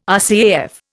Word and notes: RCAF: Indian

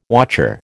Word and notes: wotcher: American English

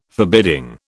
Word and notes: forbidding: British English

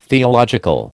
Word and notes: theological: American English

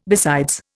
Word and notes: besides: Indian

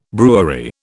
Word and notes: brewery: British English

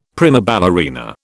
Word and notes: prima ballerina: British English

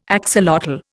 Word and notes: axolotl: Indian

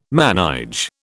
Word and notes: manège: British English

